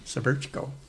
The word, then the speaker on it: Cvercko: Severchko. I had to look at it. I got the spelling, but I thought, how do you pronounce that? It was C-V-E-R-C-K-O. Severchko.